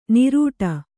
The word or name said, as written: ನಿರೂಟ